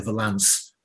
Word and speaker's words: Valance: I did actually have to check that it's pronounced Holly Valance and not Holly Valance or Holly Valance or anything like that.